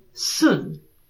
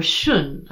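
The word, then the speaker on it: Xun: So hexagram 57, um, Sun, or Shun, I think is actually how you'd pronounce it, because in modern transliteration it's X-U-N.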